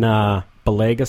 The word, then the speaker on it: Balega: And I run in Balega socks. I think that's how you pronounce it. Yeah, there you go.